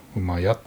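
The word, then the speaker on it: 駅家: 馬屋って読みますね。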